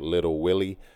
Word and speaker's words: Lil Will E: I'm pretty sure he pronounced it Little Willie, but it's spelled Little or Lil Will E.